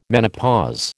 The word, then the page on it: menopause: American English